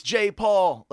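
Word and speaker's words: Jai Paul: That's the way it looks, but so the very first track on the album is some guy going like, it's J. Paul. Like, he keeps saying J. Paul. So that's, I'm taking it from the way it's pronounced on his own album.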